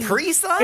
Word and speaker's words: Capri Sun: Capri Sun? – It's not Capri Sun. – No, you're putting the emphasis on the wrong syllable.